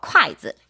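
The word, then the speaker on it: 콰이즈 (筷子): 콰이 하고 발음이 비슷해요. 콰이즈.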